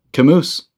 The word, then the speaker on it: Camus: "Camus." "Oh, yeah, of course. You don't pronounce the S."